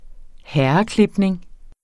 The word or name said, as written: herreklipning